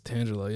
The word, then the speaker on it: Tangela: Is that how you say it, Tangela? – I think it's Tangela, yeah.